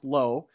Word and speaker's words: Loe: So the guy's name is Cameron Lowe, spelled L-O-E, but it's pronounced Lowe.